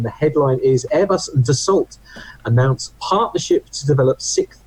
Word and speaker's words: Dassault: Dassault.